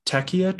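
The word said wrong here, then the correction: Tecia: I think it's Tisha. – Tisha, that makes sense.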